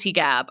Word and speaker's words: hijab: I had like 10 people reach out like, actually, in Egyptian Arabic, the J is pronounced like a G and it is hijab.